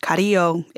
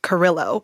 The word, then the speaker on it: Carrillo: My grandpa took this so far that he even changed the pronunciation of our last name. So instead of Carrillo, it's Carrillo. And that's how I pronounce my last name now. And I can't even really say it the correct way, which is really sad.